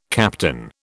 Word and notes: captain: British English

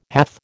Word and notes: hath: American English

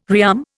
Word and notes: rearm: Indian

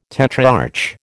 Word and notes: tetrarch: American English

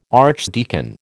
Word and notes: archdeacon: American English

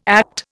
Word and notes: ACT: Indian

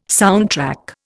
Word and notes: soundtrack: Indian

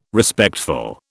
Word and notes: Respectful: British English

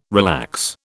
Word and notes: relax: British English

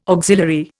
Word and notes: auxiliary: Indian

